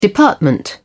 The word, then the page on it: department: British English